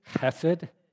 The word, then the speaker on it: Hesed: If you didn't spit on the back of the person in front of you, you didn't say it right. This is where the Dutch and the Germans have advantage over us, right? It's a throaty sound, hesed.